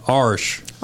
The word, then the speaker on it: Irish: Irish whiskey, or as my family in Louisville pronounces it, Arsh.